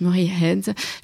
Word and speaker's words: Murray Head: En 2012, elle a fait la première partie du célèbre chanteur et acteur britannique Murray Head. Je pense que je le prononce très mal, mais elle va me corriger, je pense. – C'était très bien comme ça.